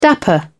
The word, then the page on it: dapper: British English